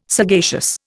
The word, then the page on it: sagacious: Indian